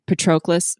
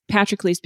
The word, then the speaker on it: Patroclus: Patrocles is how it's usually pronounced, but I've heard a lot of people say Patroclus, but it's Patrocles because it's paternal. So he's like, honor of the father is what his name means. So Patrocles, you'd put the emphasis on the first syllable.